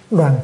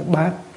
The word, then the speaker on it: đoàn: Thục năng bất đoàn thực. Chữ này đừng có đọc là chữ bát, nên đọc là chữ đoàn.